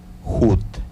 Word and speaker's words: jut: En este caso, pues es referente al ojo, que en lengua yocotán se pronuncia jut.